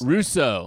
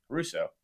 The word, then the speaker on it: Rousseau: How do you say his last Russo. – Name? Russo. Yeah, give him.